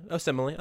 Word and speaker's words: Osemele: You don't even know how to pronounce his last name, much less how do you pronounce his last name? Have I been getting that wrong still? Osemele? – Osemele.